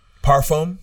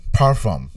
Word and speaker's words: parfum: So, yo, what, how do you pronounce this? Is it perfect? Well, it's like perfume? – Parfum. – Parfum. – Yeah. – Is that the right way to say perfume? – Parfum. Yeah. No, no, no. It's perfume and it's parfum. Two different things.